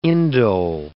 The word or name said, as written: indole